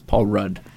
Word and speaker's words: Rudd: It's Paul Rudd. – Please. – Rhymes with Elmer Fudd.